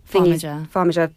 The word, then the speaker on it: Farmiga: How do I say her name? Tessa Farmiga. – Farmiga.